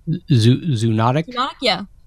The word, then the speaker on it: zoonotic: Is that how you pronounce it? Zoonotic? – Zoonotic, yeah.